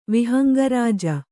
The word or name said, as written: ವಿಹಂಗರಾಜ